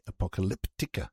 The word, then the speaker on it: Apocalyptica: And next we head off to Finland, Helsinki, for the band Apocalypta. Hang on, get it right. Apocalyptica.